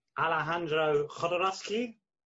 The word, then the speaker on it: Alejandro Jodorowsky: Alejandro Jodorowsky.